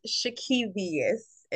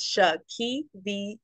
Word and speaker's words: Shekevious: My name is Shakivius. It's Shakivius. Okay? – Come on, syllables. – Yes, 10 letters, and you stretch it out, Shakivius.